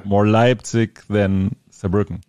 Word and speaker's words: Saarbrücken: And then in Germany, we're playing in Cologne, Hamburg, Berlin, Munich, Saarbrücken. Yeah. Am I saying that right? – Yeah.